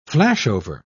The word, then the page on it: flashover: British English